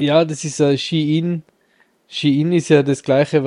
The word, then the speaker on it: Shein: Shein ist oder Schein geschrieben, aber Shein anscheinend ausgesprochen,